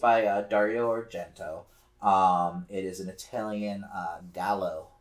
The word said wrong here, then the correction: Giallo: film. Or Giallo. I always get the um, pronunciation wrong on that.